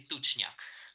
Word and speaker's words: тучняк: Нет, там был пламеняк и тучняк. Пламеняк и тучняк. На первый слог ударения.